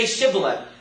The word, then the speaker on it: Shibboleth: Okay, say Shibboleth. And they're like, sh. They couldn't make that sh sound.